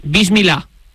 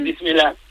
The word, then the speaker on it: Bismillah: cosa que me dijo Iria Sacomag, a ver si lo pronuncio bien, Bismillah. – Eso es. – ¿Lo he dicho bien? – Bismillah. Sí, muy bien.